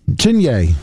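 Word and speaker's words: Chiney: Is it Chiny Okawaikmike? – Chinye.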